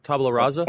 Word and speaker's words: tabula rasa: Tabula rasa? – Tabula rasa.